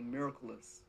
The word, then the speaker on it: Miraculous: For those of y'all that don't know, I know everybody calls me Miracle, but my first name is really Miraculous. And it's spelled like Miracle, O-U-S. And it sounds similar to the word miraculous.